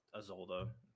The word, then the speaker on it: Isolde: Like, is that how you pronounce it, Isolde? – Mm-hmm.